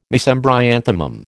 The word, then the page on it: Mesembryanthemum: American English